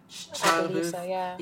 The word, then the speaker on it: Shia LaBeouf: So in a brand new film coming up called Don't Worry, Darling, he is joining the cast after, I never know how to pronounce it, it's Shia LaBeouf. Isn't that how you say it? – Shia LaBeouf, yeah.